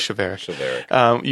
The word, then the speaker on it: chivalric: And you often find in medieval chivalric. How do you say that? Chivalric. Yeah. Let's go with chivalric.